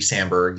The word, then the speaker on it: Samberg: and next time we're we're going to connect Andy Sandberg hopefully I'm saying that right. Andy Samberg, you know him from Saturday Night Live and uh, Brooklyn 911.